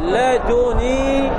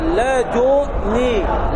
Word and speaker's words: لدني: ولكن وردت هناك قراءة، قراءة نافع أحد القراء، وقراءة أبي بكر أيضا أحد القراء في قوله تعالى قد بلغت من لدني عذرا، ورد ماذا؟ ورد التجريد، ورد التخفيف، فلدن تلفظ هكذا لدني، لدني، واضح؟ لدني، هذه قراءة نافع بالتخفيف بالتجريد،